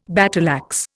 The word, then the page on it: battleaxe: Indian